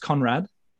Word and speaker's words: Conradt: Conradt, is it just Conradt? – Yep. – It's just spelt strangely. It's just got it's just got extra a, letters, just to be complicated. – Just a few extra silent letters.